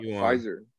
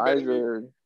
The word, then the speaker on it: Pfizer: Pfizer, Pfizer Silent, Silent P, Moderna.